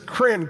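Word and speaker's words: krin (κριν): So the root of this Greek word is krin. K-R-I-N rhymes with grin. Say krin.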